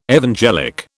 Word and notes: evangelic: British English

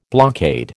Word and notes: blockade: American English

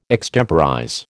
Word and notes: extemporize: American English

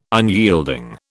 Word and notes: unyielding: British English

